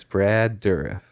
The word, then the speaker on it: Brad Dourif: So it's Brad Dourif, is that right? – Yes.